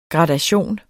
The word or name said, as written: gradation